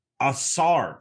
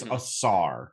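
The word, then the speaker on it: Ausar: Asar Thompson. Am I saying that right, Joey? – I have no idea. – That's how you say it. It's A-U-S-A-R Thompson. Okay. I think it's Asar.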